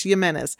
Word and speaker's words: Jimenez: Jimenez with a J. I've pronounced it wrong before, I think. – I feel like I know this one. – But it's Jimenez.